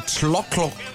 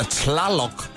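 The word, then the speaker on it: Tlaloc: De, øh, de øh, prøver at øh, hylde regnguden, der hedder Tlokklok. Eller Tlalok må det vel nærmest være.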